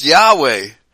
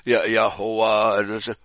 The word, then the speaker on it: Yahweh: It's not Jehovah, it's Yahweh. All right? – Mm-hmm. – There's no doubt that it's Yahweh. And some people might pronounce it slightly different, like Yahuwah.